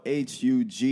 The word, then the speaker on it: Hugues: The name is spelled Hugues. No H between the G and the U.